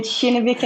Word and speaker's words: Kinnevik: I can just help and say Kinnevik and Vækstfonden.